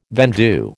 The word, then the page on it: vendue: American English